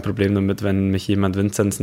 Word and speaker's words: Vinzenz: Sollen wir dann lieber Vinzenz sagen und nicht Vinzenz? Oder wie hättest du es gerne? – Ähm, ich glaube, das, das, das Thema hat sich äh, erledigt, eigentlich nach meinen ersten äh, Ausflügen äh, von Oberstdorf äh, in Richtung, äh, die, die, äh, in Richtung ganz Deutschland. Ich glaube, Vinzenz spricht man mich natürlich richtig aus, so wie meine Eltern mich getauft haben.